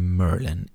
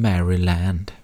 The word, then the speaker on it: Maryland: Du vet att du är från Merlin om du just uttalar Merlin, inte som Maryland. – Nej. – Utan som Merlin. – Som trollkarl.